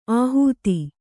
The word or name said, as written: ಆಹುತಿ